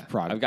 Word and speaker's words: Prague: Let's see how you pronounce it. You're correct. It's Prague.